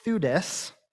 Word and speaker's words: Theudas: He mentioned Theudas, that's how you say it, um, and Judas the Galilean.